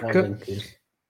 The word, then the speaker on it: Odenkirk: che lo pronuncerò malissimo, lo dico subito, Bob Odenkirk, Odenkirk.